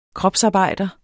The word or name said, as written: kropsarbejder